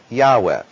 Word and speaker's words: Yahweh: But the right way to say His name, and I would opt for going that way with all of the translations of it, is to go with Yahweh.